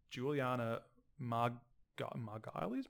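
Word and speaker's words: Julianna Margulies: which young Emily Browning takes Juliana Margulies? I wish I knew how to pronounce names. – You nailed it.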